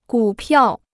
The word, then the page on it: 股票: gǔ piào